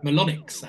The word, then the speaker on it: MELONYX: Joined by MELONYX. And that's the right way to pronounce it, isn't it? – Yes, indeed.